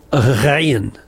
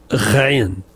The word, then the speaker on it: Ghayn: It's usually transliterated as G-H, and it's called Ghayn. Ghayn.